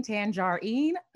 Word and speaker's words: Tanjareen: My name is spelled not like the fruit, but TANJAREEN, Tangerine. It's Official Tangerine on Facebook,